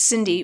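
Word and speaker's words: Cindi: It's Cindy with an I, C-I-N-D-I.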